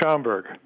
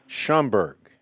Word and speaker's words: Schaumburg: It's pronounced Schaumburg. – Schaumburg. – Yes. – Got it right. The second time.